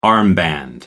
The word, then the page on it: armband: American English